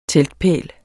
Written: teltpæl

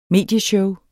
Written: medieshow

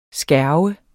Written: skærve

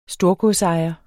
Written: storgodsejer

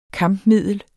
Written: kampmiddel

